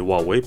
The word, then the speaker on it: Huawei: У меня, кстати, есть роутер от провайдера э, Huawei, или Huawei, правильно говорить, а, ну там я поставил пароль, конечно, другой.